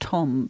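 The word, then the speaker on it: Thom: It's probably pronounced Tom, but with the TH in the beginning, I always try and pronounce it differently.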